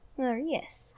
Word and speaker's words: Moeris: Is it Maurice or Morius? – Morius. – How do you spell it? – M-O-E-R-I-S. – Oh, sorry. I apologize. I thought you said Maurice in a weird way.